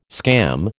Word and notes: scam: American English

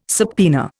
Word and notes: subpoena: Indian

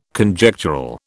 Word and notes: Conjectural: British English

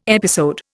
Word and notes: episode: Indian

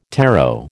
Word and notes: tarot: American English

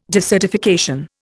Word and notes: desertification: Indian

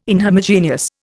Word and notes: inhomogeneous: Indian

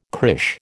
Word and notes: crÊche: American English

